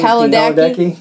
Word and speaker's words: Padalecki: Jared Padalecki. How do you say his? – I don't know. Starts with Padalecki.